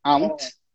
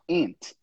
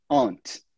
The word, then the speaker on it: aunt: Essa palavra aí, nós não pronunciamos aquele U. Então, a gente não fala aunt, a gente não fala aunt, nunca. Para quem tem inglês americano, você vai falar ant, igual formiga. Ant, ant. Para quem tem inglês britânico, aunt, tá? Mas a gente nunca pronuncia aquele U ali.